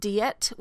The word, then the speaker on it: Diet: It's actually pronounced Diet, which means Assembly of Worms, the German pronunciation for the town in which this meeting took place.